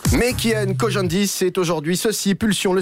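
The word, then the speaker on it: Khojandi: Alors, Kian Kojandi, c'est... – Kojandi, pardon. – C'est ça. – Exactement. – C'est la bonne prononciation.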